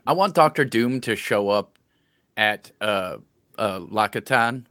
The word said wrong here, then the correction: Talokan: Is that how you say it? – Talokan? Yeah. – Talokan.